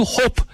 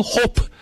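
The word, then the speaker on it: Hoppe: Ese jugador de Estados Unidos es un número 9, Matthew Hope. Le digo hope, no hope, porque tiene doble escribe? – P.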